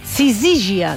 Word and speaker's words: sisígia: Ja ho havíeu dit, que es deia sísigia. – No. – A la segona. – Per tant, Sisígia.